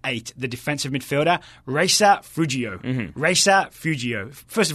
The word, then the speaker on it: Feudjio: It's spelled like feud, like family feud. uh, J I O, Feudjio.